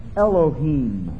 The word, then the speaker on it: Elohim: It's pronounced Elohim.